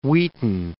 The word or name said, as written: Wheaton